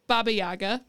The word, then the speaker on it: Baba Yaga: We said Boba Yaga. Did I say that right? – Baba Yaga, but yeah, that's Baba what he was Yaga.